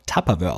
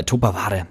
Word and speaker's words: Tupperware: Offiziell wird sie deshalb auch Tupperware ausgesprochen, denn der Mann kommt aus dem US-Bundesstaat New Hampshire. Ich bleibe jetzt trotzdem mal bei Tupperware.